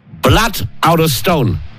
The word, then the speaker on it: Blood out of stone: Blood out of stone. Das ist richtig ausgesprochen.